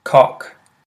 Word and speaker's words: cock: I hope it's helped you differentiate between the pronunciation of cook and cock. So it's definitely a good way of training your ears so that you can hear the slight difference in those oh and ooh vowel sounds in English,